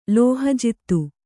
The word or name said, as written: ಲೋಹಜಿತ್ತು